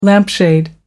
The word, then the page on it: lampshade: American English